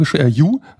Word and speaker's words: Hjo: Wir waren in der Stadt Jo, also H-J-O, Ju ausgesprochen, H-J-O an dem rechten See in der Mitte ungefähr.